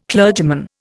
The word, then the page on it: clergyman: Indian